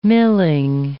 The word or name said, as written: milling